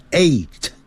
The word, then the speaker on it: eight: 要把上牙齿咬的下嘴唇发 v 的音 seven seven，八 e i g h t，那个好念 g h 不发音，念成 eight eight eight，九呢，因为是 n i n e，所以前面有个 n 就要念成 n。